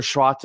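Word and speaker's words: Schwarz: We're five games into the Sandro Schwartz era, and that's apparently how you're supposed to pronounce it, Schwartz, not Schwartz. I learned that from Kev Egan.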